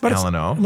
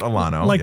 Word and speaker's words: Alano: It's Alano, like, not Alano. – The Alamo. – Yeah, kind of, yeah. – Right. – Yeah. – I don't know why we were thinking Alano.